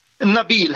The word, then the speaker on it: Nabil: Jag vill få hjälp med att uttala ditt namn. Nabil eller Nabil? – Nabil heter jag.